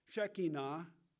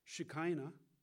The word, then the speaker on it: Shekinah: And it says this, that when it lined upon them, God's Shekinah, or some people pronounce Shekinah, his glory, his presence was there, dwelling within their holy temples, their bodies.